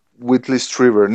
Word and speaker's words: Whitley Strieber: Whitley Strieber.